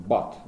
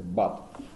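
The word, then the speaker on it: but: Например, э, слово там бат, да, оно пишется b u читается бат. А точно так же пишется слово пут, p u Это бат, а это пут.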